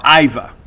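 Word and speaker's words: Aiva: Well, one of the stories that we're continuing to track is the portal recruitment of former Washington middle infielder Ava Arquette. That's A-I-V-A. Ava Arquette, or Iva, probably the better pronunciation.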